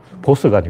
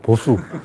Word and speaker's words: 보수: 보스가 아니고 보수.